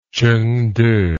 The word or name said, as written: chengde